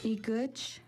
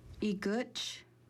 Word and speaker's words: Igutch: Igutch is how you say nose in Kixt. Igutch, Igutch.